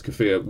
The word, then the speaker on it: kafir: Kafar is the word for infidel. – Maybe I mispronounced it. Yeah, it's kafir.